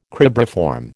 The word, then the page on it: cribriform: American English